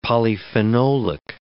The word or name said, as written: polyphenolic